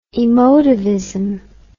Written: emotivism

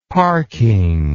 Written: parking

